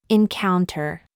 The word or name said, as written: mouth